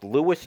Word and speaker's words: Lewes: He corrected me one time on the pronunciation. I think it's Lewis, Delaware, L-E-W-E-S.